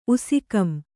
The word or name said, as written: ಉಸಿಕಂ